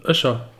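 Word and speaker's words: Öcher: Wie spricht man das richtig aus? – Öscher. – Öscher? – Ja. – Öscher. Ich sag immer Ohre. Ist das verkehrt? – Nee, Öscherjung. – Öscher, okay.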